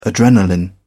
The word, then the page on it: adrenalin: British English